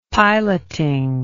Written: piloting

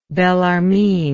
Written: Bellarmine

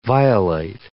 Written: violate

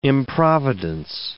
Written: improvidence

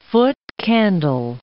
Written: foot-candle

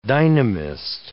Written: dynamist

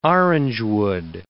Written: orangewood

